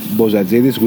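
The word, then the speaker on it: Bozatzidis: So, my email address would have been Bozadzidis,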